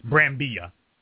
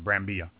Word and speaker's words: Brambilla: It's hard for me to pronounce his last name. It's Brambilla. I believe is how you pronounce his last name, is Brambilla.